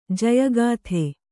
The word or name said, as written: ಜಯಗಾಥ